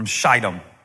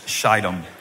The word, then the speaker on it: Shittim: Then Joshua, son of Nun, don't tell everybody, but just secretly sent two spies from Shittim. And that's a long I when you pronounce Shittim.